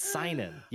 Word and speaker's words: seinen: I said the title for the sole reason that you fuck up everything, and you fucked up a word that you've said like 50 times already. It's seinen. Yes.